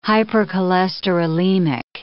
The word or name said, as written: hypercholesterolemic